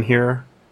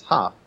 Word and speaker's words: hearth: See, I've said her, but I've been corrected. I've been pulled up on that in my regular life. – Oh, really? – Because okay. It's hearth. – Yeah, it's like the heart of the home is the hearth.